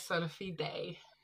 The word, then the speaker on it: Sola Fide: Yes, Sola Fide.